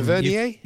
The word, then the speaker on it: Tavernier: The player himself, and pundits, etc., pronounce it Tavernier. I can understand why you went down the French route, Tavernier, but Tavernier.